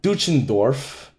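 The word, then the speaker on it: Deutschendorf: Born Henry John Duchendorf Jr. Did I say that right? – Yeah.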